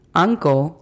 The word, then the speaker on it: uncle: Uncle significa tío. La pronunciación fonética de esta es A-N-K-O-L. Uncle. Recuerden hacer la pronunciación correcta de la letra L. Ponemos nuestra lengua en la parte posterior del paladar.